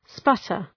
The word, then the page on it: sputter: ‘spʌtər